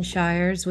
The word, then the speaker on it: Shires: I am thrilled to have Jason Shires with me today.